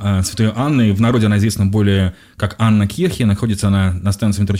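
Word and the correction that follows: Анненкирхе: Замечательный Евгений Раскатов придет вам инструкцию, что правильно говорить Аннен Кирхи. – Аннен Кирхи, да.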